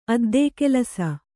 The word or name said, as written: ಅದ್ದೇಕೆಲಸ